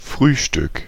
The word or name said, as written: breakfast